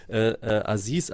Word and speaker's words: Aziz: aber Aziz als Nachname ist natürlich immer schwierig, A-Z-I-Z, wie spricht man das jetzt aus?